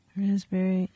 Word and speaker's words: raspberry: K-N-E-E-L, Neil. – Raspberry, answer. – Silent right. letters. – Yes, silent letters. Right. Raspberry is the silent S. – P. – Well, P. Sorry. – Silent P, right?